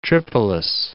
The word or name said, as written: Tripolis